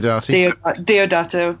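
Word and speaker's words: Diodati: The Haunting of Villa Diodata. I can never say it right. – Diodata. – Yeah.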